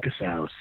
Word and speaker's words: Casaus: Everything is Martin Casaus, C-A-S-A-U-S.